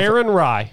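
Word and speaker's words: Aaron Rai: The guy on the European Tour, pronounce his name again one more time. – Aaron Rye. – Aaron Rye? – Rye. – I thought there was a D in there somewhere.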